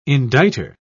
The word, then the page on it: indictor: British English